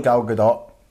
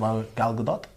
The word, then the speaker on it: Gal Gadot: No, probably Gal Gadot. That's how she pronounces it. – Gal Gadot.